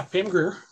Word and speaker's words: Grier: Yeah, Pam Grier. – Because it's Grier, and my brain says it's going to be Grier, and then my memory goes, no, it's Greer.